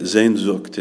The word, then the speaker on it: Sehnsucht: It's called Sehnsucht. I had to check with my wife to make sure I was pronouncing that properly. That's how you say it, Sehnsucht.